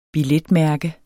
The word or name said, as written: billetmærke